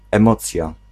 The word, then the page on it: emocja: [ɛmɔt͡sja]; /ẽmocʹi ̯a/